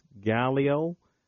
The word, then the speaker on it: Gallio: There is a person named very similar, but I believe the pronunciation is Galileo, and it's spelled G-A-L-L-I-O.